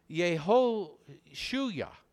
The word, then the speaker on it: Yehoshua: Joshua, which is actually pronounced Yehoshua. We've made it Joshua.